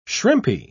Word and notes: shrimpy: British English

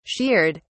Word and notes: sheared: British English

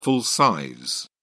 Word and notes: full-size: British English

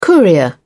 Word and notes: courier: British English

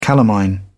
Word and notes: calamine: British English

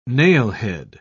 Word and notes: nailhead: British English

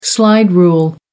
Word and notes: slide rule: American English

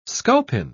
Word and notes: sculpin: British English

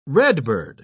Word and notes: redbird: British English

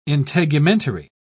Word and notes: integumentary: British English

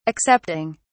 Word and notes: accepting: British English

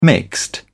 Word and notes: mixed: British English

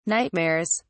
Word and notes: nightmares: British English

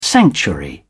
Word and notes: sanctuary: British English

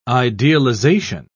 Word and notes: idealisation: British English

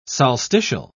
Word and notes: solstitial: British English

